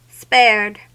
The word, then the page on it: spared: American English